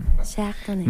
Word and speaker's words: Chardonnay: Mayo es el mes del Chardonnay. – Chardonnay. – Claro, estaba esperando tu pronunciación Chardonnay. – Más, un poquito más precisa.